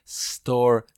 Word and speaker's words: store: Cuando vaya una S más consonante, se hace el sonido de la S. No se dice S, se dice store, school, stay.